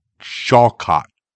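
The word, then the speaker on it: Shawkat: How do you pronounce her last name? Alia Shawcott. There you go.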